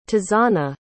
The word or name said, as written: tazanna